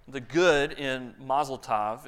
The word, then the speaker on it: tov: There's a Hebrew word that's pronounced tov, it's been kind of taught to me, tov. Everyone say this together, tov. T-O-V, tov.